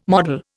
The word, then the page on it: model: Indian